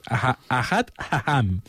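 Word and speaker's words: Ahad Ha'am: Es una mica difícil de pronunciar, es Ahad Aham padre del sionismo cultural,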